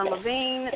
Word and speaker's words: Laveen: My name is Tiana Levine. If you're interested in my work, my last name is spelled L-A-V-E-E-N. You can find me on Facebook or Twitter.